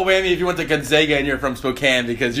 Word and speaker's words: Gonzaga: The funny thing about the pronouncing, is it Gonzaga? – Gonzaga.